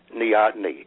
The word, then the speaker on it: neoteny: Well, one other question I have, and I hope I'm pronouncing this right. What is neoteny? – Neoteny. – I'm glad. Yes, thank you.